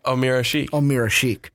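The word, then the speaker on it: Ömer Aşık: ESPN loves to say his name wrong when Austin Chicago know how to really say it. – Omir Ashik. Yeah.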